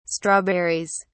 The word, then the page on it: strawberries: British English